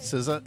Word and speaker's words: SZA: Last year, I think I said Sia instead of SZA, but SZA is 35 years old today.